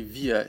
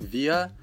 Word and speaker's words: wir: Люди, которые учили немецкий в школе, часто говорят, что вот нас учили говорить просто «вир», «ир». Но, как вы понимаете, в школах не всегда уделяют внимание лучшему варианту произношения. Поэтому на самом деле должны эти местоимения произноситься именно так – «виа», «иа».